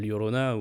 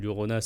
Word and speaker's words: Llorona: Donc, euh, mais c'est la Llorona ou, ou Llorona si, vous prononcez un peu moins bien, mais voilà.